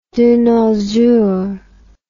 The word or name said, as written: de nos jours